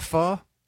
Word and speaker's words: pho: Oh, you pronounce it pho? – It's pronounced pho. – Even though it's spelled pho? – Yes.